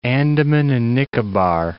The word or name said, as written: Andaman and Nicobar